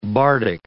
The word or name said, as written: bardic